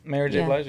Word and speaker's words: Mary J. Blige: Honestly, the only artist I didn't, like, don't know like that is Mary J. Blige. Did I say her name right? – Mary J. Blige, yeah.